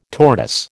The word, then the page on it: tortoise: American English